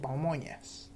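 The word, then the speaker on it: pamonhas: En Brasil se llaman pamojas o pamoñas, pronunciado en portugués.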